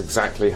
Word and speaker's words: exactly: That's exactly how I feel. – Esta es una expresión para mostrar que estás de acuerdo. En la palabra exactly, vamos a subir la entonación en la segunda sílaba. Exactly.